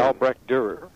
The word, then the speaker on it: Albrecht Dürer: Albrecht Dürer.